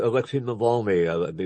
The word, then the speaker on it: Navalny: Alexei Navalny, if you should pronounce it.